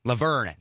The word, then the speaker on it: Laverne: 1972, Laverne Cox, don't know if I'm saying that right. – Laverne, that's what it is.